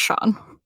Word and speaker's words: Schon: Their friend Sean, which is spelled S-C-H-O-N, which is, I've never seen it spelled that way before, but I guess it's pronounced Sean.